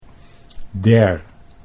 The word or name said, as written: der